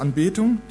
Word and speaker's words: Anbetung: Und was dann geschieht, diese Anbetung, ist in dieser Betonung, erste Silbe, zweite Silbe,